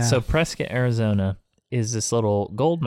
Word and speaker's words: Prescott: But so it's spelled Prescott, but the people there say Prescott.